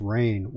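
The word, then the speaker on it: Rahne: I know one of them was Ricky, I believe the last name is pronounced Rain.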